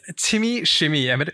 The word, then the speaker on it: QimmyShimmy: But with me today is QimmyShimmy.